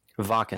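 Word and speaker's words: Wacken: Right now I have stuff up for the, what is it pronounced? Vakken? – Vakken.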